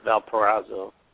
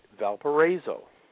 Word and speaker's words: Valparaiso: At Valparaiso. – Not Valparaiso. Let's talk about that. Valparaiso. – Valparaiso. I got to say it right while I'm there.